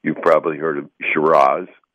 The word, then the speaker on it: Shiraz: And the nomenclature difference really comes from Australia, where the Syrah grape, they pronounce Shiraz, S-H-I-R-A-Z.